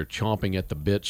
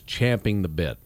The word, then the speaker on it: champing the bit: That originated, it's a horse, it's horse lingo. How it started is champing the bit. That's the actual... That's what you call when a horse has the bit in his mouth and he's eager to go and he's chewing on it. They call it champing the bit.